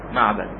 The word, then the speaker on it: معبد: يكتب في آخرها، لكن ابن مكولة مثلا يذكر في الاشتباه مع معبد، يذكر في الاشتباه مع معبد، يقول هناك معبد ومعيد، انتبه، معيد هذه بالياء، معبد بالباء، ومعبد بفتح الميم، لكن معيد بالضم، صحيح؟ إذا هي تختلف في الحرف الثالث هذا بالياء والآخر بالباء، وأيضا في النطق هذا بضم أوله ضم الميم والآخر بفتح.